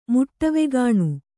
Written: ಮುಟ್ಟವೆಗಾಣ್

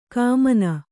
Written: ಘಮ್ಮನ

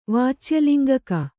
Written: ವಾಚ್ಯಲಿಂಗಕ

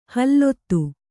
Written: ಹಲ್ಲೊತ್ತು

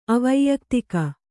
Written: ಅವೈಯಕ್ತಿಕ

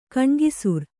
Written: ಗಣಾಕ್ಷರ

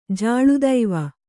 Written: ಜಾಳುದೈವ

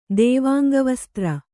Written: ದೇವಾಂಗವಸ್ತ್ರ

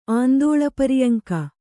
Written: ಆಂದೋಲಪರಿಯಂಕ

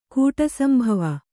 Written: ಕೂಟಸಂಭವ

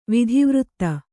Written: ವಿಧಿವೃತ್ತ

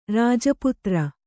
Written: ರಾಜಪುತ್ರಿ

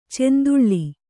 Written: ಚೆಂದುಳ್ಳೆ

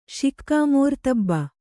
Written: ಶಿಕ್ಕಾಮೋರ್ತಬ್ಬ

